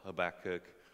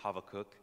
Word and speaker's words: Habakkuk: And the first prophet that we're starting with is Habakkuk, or Habakkuk. Or if you want to do the Hebrew, it's Habakkuk. Okay, so whatever you want to pronounce it with, but I may go back and forth, so just bear with me on that.